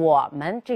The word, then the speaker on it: 我们: 第一呢，对于这些必读轻声词啊，不管是语法层面上的轻声词，还是词汇层面的轻声词，我们都应该读作轻声。比如“ 我们” 这个词，它是一个必读轻声词，但是在平时呢，经常听到有些同学在朗诵的时候，哎，读成“ 我们”。首先没有做轻声处理，另外呢，把门呢拖长音了，这是不恰当的。